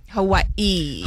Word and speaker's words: Hawaii: Hawaii. – Hawaii. That's how you say it.